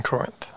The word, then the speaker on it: Corinth: and he's telling the people in Corinth, that's how you pronounce it, right? – The place? Yes, in Corinth.